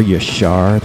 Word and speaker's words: Yashar: Are Yashar, that's how you pronounce it.